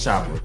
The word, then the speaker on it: Chopper: Did you say an A or an ER at the end of that? Chopper. I think I heard the ER. – Chopper. – How would you say it? It's Chopper.